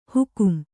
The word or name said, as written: ಹುಕುಂ